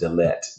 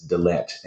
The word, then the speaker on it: Dalet: And the number four is represented by a letter that is pronounced Dalet, Dalet.